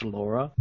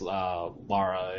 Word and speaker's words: Lara: Is this the one where they call Lara Croft Laura? – Depends which character talks. If it's the English ones, it's pronounced Lara. If it's Laura. – If it's the American characters, it's not Laura.